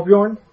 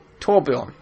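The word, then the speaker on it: Torbjörn: Troborn. The dwarf who builds in Overwatch. – Oh, Torbjorn? – Torbjorn.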